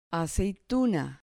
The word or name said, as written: aceituna